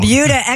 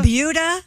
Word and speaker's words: Buda: It's called the Buda... – It's spelled B-U-D-A, and I looked it up. It is pronounced like beauty.